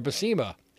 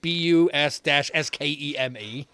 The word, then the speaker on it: Buscema: And I've heard people pronounce it both ways, Buscema or Buscema. And in the book, he actually says, no, it's not Buss-Skema. It's Buscema.